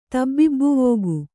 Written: ತಬ್ಬಿಬ್ಬುವೋಗು

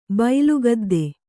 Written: ಬೈಲುಗದ್ದೆ

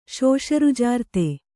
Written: ಶೋಷರುಜಾರ್ತೆ

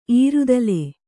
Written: ಇರ್ತಲೆ

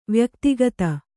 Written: ವ್ಯಕ್ತಿಗತ

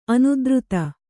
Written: ಅನುದ್ರುತ

